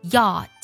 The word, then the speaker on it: yacht: 按照拼写感觉读 yacht，但其实呢 ch 不发音，游艇这个单词就读作 yacht，你没有听错啊，游艇的 ch 不发音。